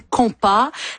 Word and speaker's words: compas: Vous nous parlez de compas, ce genre musical haïtien mis à l'honneur aujourd'hui. – Oui, ça s'écrit de plein de différentes façons, mais on dit compas.